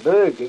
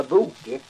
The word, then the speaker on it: böge: Det är uttalet på det böge istället för boke.